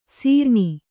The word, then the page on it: ಸೀರ್ಣಿ: sīrṇi